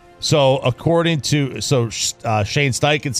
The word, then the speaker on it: Steichen: Oh, now, don't say it like that. – So like, Shane Steichen. – Go ahead. – Talking about his boss. – Shane Steichen said it's got to be credible.